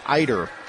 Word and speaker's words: Ieuter: I-E-U-T-E-R, Brigitte Eider.